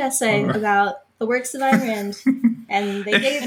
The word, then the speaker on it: Ayn Rand: It is Ayn Rand.